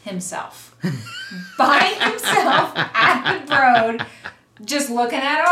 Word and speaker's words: Broad: Spelled Broad, pronounced Broad. Mm-hmm. It's like a Houston, Houston thing.